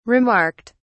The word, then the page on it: remarked: British English